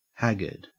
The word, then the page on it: haggard: Australian English